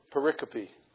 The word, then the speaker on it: pericope: Is it bisyllabic or quadrisyllabic? It's quadrisyllabic. It's pronounced pericope.